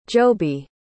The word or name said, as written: jobey